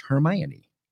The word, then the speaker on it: Hermione: She will also back up the fact that the name is pronounced Hermione.